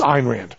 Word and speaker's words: Ayn Rand: It's Ayn Rand. It rhymes with mine. Mine, Ayn Rand.